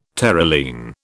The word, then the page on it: Terylene: British English